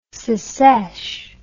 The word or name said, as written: secesh